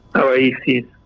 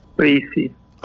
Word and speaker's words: Raisi: Rossi? – Raisi. – Raisi? – Raisi.